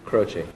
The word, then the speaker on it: Croce: It's spelled C-R-O-C-E. Croce. Croce. Exactamente igual que lo está pronunciando Bang Bang.